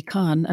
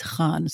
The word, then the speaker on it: Khan: You know, that's not bad, actually, Khan. I mean, the only difference I would say is Khan, so it's a kh.